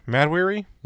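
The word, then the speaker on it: Mahd Wy'ry: How do you pronounce it? Because it's spelled so weird. Mad Weary? – Yeah. – It's spelled M-A-H-D for the mad. Mm-hmm. And then Weary is spelled W-Y apostrophe R-Y.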